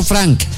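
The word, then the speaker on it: Francke: Bueno, lo pronunciamos el apellido como se lee, ¿no? Porque es Pedro Franque.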